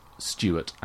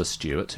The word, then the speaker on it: Stuart: As well, but it's the third high steward in Scotland that adopts Stuart as a surname.